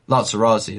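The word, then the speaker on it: Latts Razzi: You look at Lazarette, is that how you pronounce the name? – Lazarazi, that's it.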